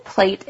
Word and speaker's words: plate: 盘子的发音你说对了吗？Plate，它是发长音的 a。Plate，很多人会不小心发成 plate 或者是 plat，应该是 plate 这个长音的 a。我们来好好练习一下，请你第一次先听我念，第二次的时候试着跟我一起来念 plate。Plate, gray, gray.